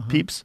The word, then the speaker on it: Pepys: Papais? How would you say that? – Peeps. – Peeps. – Uh-huh. – Peeps.